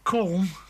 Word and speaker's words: Colm: This is Colm, I'll just spell it, C-O-L-M, Colm Kirwan, and Jumping In.